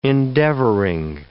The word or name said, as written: endeavoring